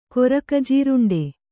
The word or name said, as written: ಕೊರಕಜೀರುಂಡೆ